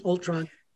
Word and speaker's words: Ultron: Ultron.